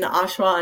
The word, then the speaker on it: Oshawa: Tell us about, how can people find you ladies if they are in Ontario, if they are in Oshawa? Did I say that right? – You did. Oshawa. You said it right.